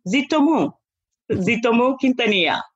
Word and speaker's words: Zoutomou-Quintanilla: I mean, as you know, I'm originally from West Africa. So Zoutomou is the easiest way to say it. And Zoutomou-Quintanilla.